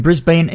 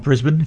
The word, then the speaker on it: Brisbane: Then from Brisbane in Australia, or Brisbane if you want to pronounce it properly, Sound Affliction and The Path.